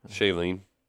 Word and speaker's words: Shailene: Shailene.